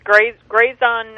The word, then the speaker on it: Grazon: I know we had talked a few weeks ago about is it Grazon? Is that how you pronounce it? – Yes, Grazon. – Grazon, okay.